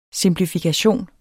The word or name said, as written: simplifikation